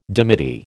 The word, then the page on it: dimity: American English